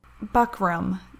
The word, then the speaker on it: buckram: Buckrow? Buckram. B U C K R A M, I think.